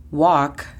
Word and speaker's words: walk: La pronunciación fonética se escribiría U-A-K, walk. Si has visto esta palabra escrita correctamente en inglés, sabes que hay una L, pero como puedes escuchar, parece que la L es muda. Entonces es U-A-K, walk.